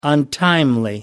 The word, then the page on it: untimely: American English